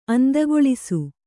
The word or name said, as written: ಅಂದಗೊಳಿಸು